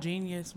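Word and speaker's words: jeen-yuhs: It's called Genius, but it's spelled like J-E-E-N hyphen. – That what they say? – Genius, yeah.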